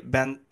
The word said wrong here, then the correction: Banzai: I can't ever pronounce their names, Chris. – Shenzi, Banzai, and Ed. – Yes, thank you.